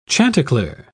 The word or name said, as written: chanticleer